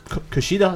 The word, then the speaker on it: Kushida: We'll be defending it against Hiromu Takahashi, Kushida, is that right pronounced? – Yeah.